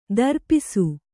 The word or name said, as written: ದ್ರಪ್ಸ